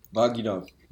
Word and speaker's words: Vagina: Wo ist die Betonung? Auf war, auf gi oder auf na? – Auf gi. – Vagina. – Ja, wie im Englischen auch Vagina.